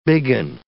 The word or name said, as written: bigging